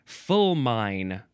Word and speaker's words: fulmine: All right, moving on to fulmine. No, it says you're supposed to pronounce it fulmin, and it is spelled fulmine.